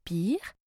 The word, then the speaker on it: pier: La ville est connue pour sa jetée de fêtes foraines, pier, qui se prononce pier, sa tour de l'horloge, clock tower, clock pour horloge, et tower, je vous laisse deviner.